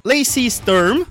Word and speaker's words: Lacey Sturm: Lacey Sturm, falei certo agora, que é a ex ex-vocalista do Flyleaf.